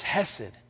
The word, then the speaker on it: hesed: But the word is hesed. You would spell it in English, H-E-S-E-D.